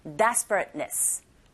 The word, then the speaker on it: desperateness: 요거 발음하실 때 앞부분에 광세 주시고 뒷부분에 응 한번 끊기면서 ness 하셔야 되거든요. desperateness, desperateness 이렇게 하시면 됩니다.